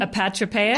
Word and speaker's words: apotropaic: And what are some examples of the apotropaic, and am I saying that right? Say it again. – Apotropaic? – Apotropaic. – There you go.